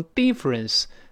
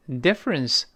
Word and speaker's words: difference: 所以可数不可数都可以，但是不能读成 difference，它不是发一。我们再次强调，中间发 e e d difference，不要读 difference 啊。